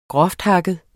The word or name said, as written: grofthakket